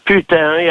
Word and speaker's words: putain: Różnica między nimi jest ta, że Putin, czyli między tymi bandytami, że Putin, po francusku putain, jak mówiłem, to znaczy putain, to znaczy kurwa. To się pisze tak samo, a czyta się inaczej.